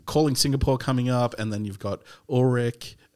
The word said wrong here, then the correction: Utrecht: Did I pronounce that right? – Utrecht just finished.